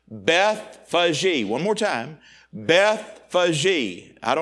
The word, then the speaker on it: Bethphage: And when they drew nigh unto Jerusalem and were come to Bethphage, I've always mispronounced that, but I've learned the proper way to say it now.